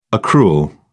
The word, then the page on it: accrual: British English